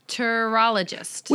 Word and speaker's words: Chiropterologist: Chiropterologist.